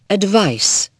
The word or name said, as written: advice